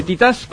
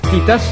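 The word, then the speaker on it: Titãs: Parece que en portugués Titás se pronuncia Chitans, no sé, bueno.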